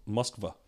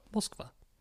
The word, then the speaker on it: Moskva: Is it Moscow? – CSKA Moskva. – Moskva.